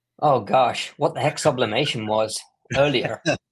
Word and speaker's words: sublimation: There's always somebody that says, I have a sublimatic printer, or I do sublimination.